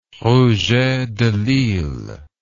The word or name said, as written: rouget de lisle